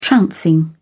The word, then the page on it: trouncing: British English